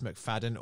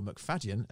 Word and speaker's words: McFadden: dissecting our episode with Jess McFadden, or McFadden, as it should be pronounced,